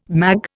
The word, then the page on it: mag: Indian